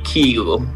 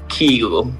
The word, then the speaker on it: Kygo: Ach, ik zei het weer verkeerd. Ik zei Kygo, hè? Nou, het heeft dus een andere uitspraak. Luister maar naar Kygo, die dat zelf zegt in het Noors. – I guess it's just like the Norwegian pronunciation would be Kygo. – Ja, en dat is het land waar hij vandaan komt. Dus voortaan is het Kygo.